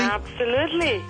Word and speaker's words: Tamar: Hi, Tamar. – Hi, Hal. – Am I pronouncing your name correctly? – Absolutely.